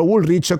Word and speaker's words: Woolrich: Ciao Chiara, con il mio primo stipendio dignitoso nel 2008 mi sono comprato un giaccone della, come cavolo si pronuncia, non mi ricordo mai, della Woolrich. Oh, qualcuno sa la pronuncia qui dentro? Woolrich, confermate. Bravissimi tutti,